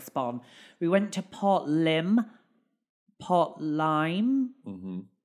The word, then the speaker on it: Lympne: I think it's Port Lyme. How you meant to pronounce it.